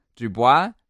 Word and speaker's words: Dubois: What was that surname? Dubois? – I mean, that's where I've gone with it. Let me spell it for you. – D-U-B-O-I-S? – You got it. You nailed it in one.